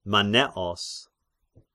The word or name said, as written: Manneos